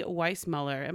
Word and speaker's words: Weissmueller: My guest today, you guys, is Lindsey Weissmueller.